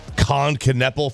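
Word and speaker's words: Knueppel: You could be taking Con Knepel from Carol from Duke. – You pronounce the K? – They do.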